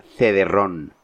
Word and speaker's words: cederrón: La palabra cederrón. Mm. Que, escrito como suena, C-E-D-E-R-R-O-N, ¿no? – Y acento en lago, supongo. – Acento en la por supuesto.